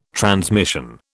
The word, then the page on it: transmission: British English